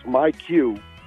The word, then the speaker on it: myQ: So the app, it's not a typo. It's pronounced MyQ.